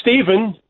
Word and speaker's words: Stephen: I well, it's Stephen.